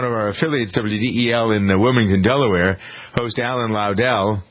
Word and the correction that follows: Ludell: It's Alan Ludell. I got to pronounce his name correctly.